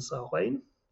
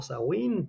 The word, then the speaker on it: Samhain: It is the first of the four seasonal festivals of the Gaelic, along with, as we said before, Beltane, Lugnasa, and Samhain, or Samhain, depending on what country you come from. They all have different pronunciations depending on where you're coming from.